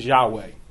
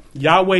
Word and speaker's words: Yahweh: YHWH. The, just the four consonants, YHWH. And we are pretty sure that the pronunciation is Yahweh. Simply because you have things like hallelujah, words like that. And there's in the in the Greek translation, there's different things that give us understanding that it was actually pronounced Yahweh.